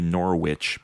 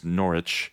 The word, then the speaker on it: Norwich: And that's with a silent W. Ooh. What Americans would say Norwich, but it's Norwich, and at the Arts Center.